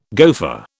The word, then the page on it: gofer: British English